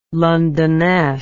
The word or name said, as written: Londonesque